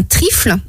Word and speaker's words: trifle: Et là, je vous présente un trifle. – Un trifle, donc ça s'écrit T-R-I-2-F-L-E? – Non, T-R-I-F-L-E.